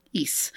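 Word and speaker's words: Ys: But it's like the word geese without the G at the beginning. East.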